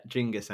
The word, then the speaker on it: Genghis: Genghis, actually. That's how they pronounce it.